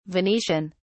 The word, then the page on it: Venetian: British English